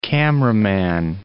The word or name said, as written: cameraman